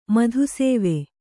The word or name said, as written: ಮಧುಸೇವೆ